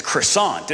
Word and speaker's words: croissant: How do you say this word in French? – Croissant. – No, it's croissant, isn't it? – No. Croissant.